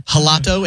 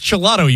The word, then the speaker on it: gelato: And I thought it was like a soft G, like you might pronounce a J in Spanish. So I was like, I'll have the gelato. And he's like, the gelato. Yes, yes. – Yes, yes. – Yes, yes. Yes, it's just gelato, you idiot.